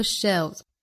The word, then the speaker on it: shelves: on all the shelves. Shelf 这个词呢变成了复数，读成 shelves, 还要注意单词内部的这个舌侧音不能丢，舌尖要去抵一下上齿龈的 shelves.